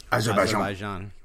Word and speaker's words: Azerbaijan: Azerbaijan. – Finally. They've been off for 3 weeks or so. So, yeah. How do you pronounce that again? – Azerbaijan.